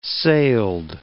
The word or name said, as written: sailed